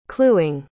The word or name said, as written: cluing